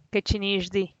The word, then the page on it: ketxi nixdi: Teotitlán del Valle Zapotec